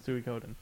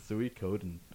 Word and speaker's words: Suikoden: I don't even think that's a proper pronunciation. But no, that's definitely the way you pronounce it, Suikoden. – Suikoden.